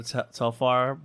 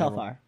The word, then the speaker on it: Telfar: Did you see the TikTok of the Asian girl who had her Telfar bag stolen? – Mm-mm.